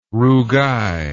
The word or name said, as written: rougail